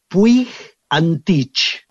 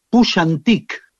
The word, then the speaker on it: Puig Antich: Y uno se llamaba de apellido, eh, según lo diríamos nosotros, Puig Antich, eh, de apellido, ¿no? Y en realidad se pronunciaba Puig Antich muy diferente.